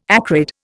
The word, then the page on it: acrid: Indian